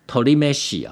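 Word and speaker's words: 鶏めし: 这个鸡饭呢它日文发音叫做 torimeshi、啊、 tori 应该就是鸟的意思、啊、叫鸡饭。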